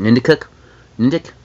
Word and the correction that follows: Niantic: Whatever. – I think it's Niantic or something like Niantic. – That. – Niantic? I can see, yeah, that makes sense.